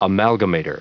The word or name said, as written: amalgamator